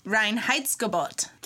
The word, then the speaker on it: Reinheitsgebot: But you pronounce it in German, Reinheitsgebot.